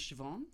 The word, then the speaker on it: Siobhan: Her name is Siobhan.